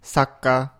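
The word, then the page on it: sakka: Japanese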